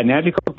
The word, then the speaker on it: Nanticoke: Yeah, Nanticoke, Pennsylvania.